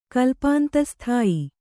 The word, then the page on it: ಕಲ್ಪಾಂತಸ್ಥಾಯಿ: kannada